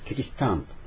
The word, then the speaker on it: キリシタン: それからクリスティアンと、ポルトガルを聞いてクリスチェ、キリシタンとアンという鼻音が入ってですね、キリシタン、キリシタンということ、キリシタンとクリスチャンのポルトガル語発音が日本語になまったわけですね。